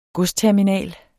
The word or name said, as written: godsterminal